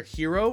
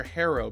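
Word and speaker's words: Herro: Is it Tyler Hero or Tyler Harrow? Because I've been saying Harrow. – I think it's Hero. I've heard Hero a bunch of times. – See, I'm listening to broadcast. I'm listening to Calabro say Hero. – Oh. – But okay. I also hear him mispronouncing names all the time. – It's all right, man. It's all right. I think it's a tomato situation with us. I'm going to call him Hero though.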